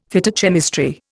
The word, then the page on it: Phytochemistry: Indian